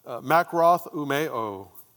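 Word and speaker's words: makroth umeo (μακροθυμέω): It's a makroth umeo, is how it's said, which means to be long-suffering, to defer anger.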